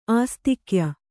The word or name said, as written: ಆಸ್ತಿಕ್ಯ